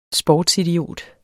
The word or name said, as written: sportsidiot